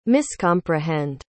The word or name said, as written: miscomprehend